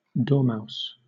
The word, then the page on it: dormouse: British English